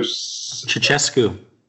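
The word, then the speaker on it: Ceaușescu: The overthrow of, how do you say his name? So Ceausescu. – Ceausescu reigned from 1965 till he was overthrown and executed in 1989.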